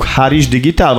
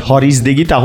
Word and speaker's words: Haris Digital: Nagyjából szerintem sikerült jó nevet választani, hogy működik Magyarország itt akár Haris digitálként kiejtve. Eh, most, ahol a kacsingatunk az USA-ból jelentően Harris digitálként halljuk vissza, úgyhogy működik a név talán. Eh, mind a kettő jó.